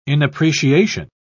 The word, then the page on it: inappreciation: British English